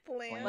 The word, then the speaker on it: Blanche: I like Blanche, actually. – Blanche. – Like Golden Girls. – Maybe that's how I was just pronouncing that name. – Yes. It's Blanc. Blanche, but pronounced Blanc.